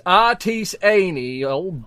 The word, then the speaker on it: artisanal: We was pronouncing it the incorrect way the entire time. She said, you're saying it wrong. I said, I ain't not saying it wrong. I know what I'm saying. We was saying it wrong. So I went up and I said, pardon me, miss. Do you have any poppy seeds in these artisanal bagels? Because I pronounced that word correctly.